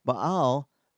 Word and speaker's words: Baal: But they had made Baal, or Baal, it's pronounced in Hebrew, Baal, their God.